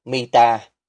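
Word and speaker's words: mita (מִטָּה): Từ ngữ Hebrew được dùng ở trong Sáng Thế Ký đoạn 47 câu 31 có thể mang nghĩa là giường khi được phát âm là mita, mà cũng có thể mang nghĩa là gậy khi được phát âm là mathe.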